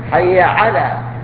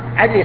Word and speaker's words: حي على: ومنها على، حي على، يغيرها علي الصلاة، يغير الحرف اسم، علي اسم رجل وعلى حرف جر، فيقول حي علي الصلاة.